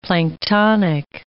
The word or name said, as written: planktonic